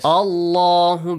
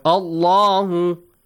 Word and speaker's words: الله: The letter lam in the word Allah, Hussein? Yeah? It has to be pronounced? – Heavily. – Heavily, yes. – Yes. – Allahu, because Hamzatul Wasl, it starts with a second letter, which is lam, and you have Hamzatul Wasl in order to read it.